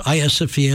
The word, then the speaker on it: Hagia Sophia: It looks like Hagia Sophia. It's pronounced Hagia Sophia.